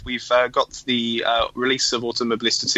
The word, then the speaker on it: Automobilista: Automobilista.